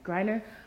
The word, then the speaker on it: Griner: Griner.